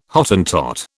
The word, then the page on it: Hottentot: British English